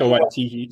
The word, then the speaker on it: Waititi: This hit right now, Love and Thunder, even though Taika Waititi, I can never pronounce his last name. – Taika Waititi, yeah.